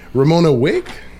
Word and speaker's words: Romona Wigg: I am your host, and joining me today I have Romona Wigg.